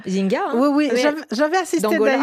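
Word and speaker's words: Nzinga: Voilà le vrai prononcé de ce nom, c'est Anne Zinga.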